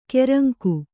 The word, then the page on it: ಕೆರಂಕು: kannada